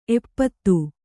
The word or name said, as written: ಎಪ್ಪತ್ತು